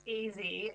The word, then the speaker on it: Ezzy: One of the other stories that was quite delightful was how you highlighted, is it Ezzy? Is that how you pronounce it? Ezzy Moses' legendary spice shop in Bondi. – So it's pronounced Easy, as in it's short for Ezekiel.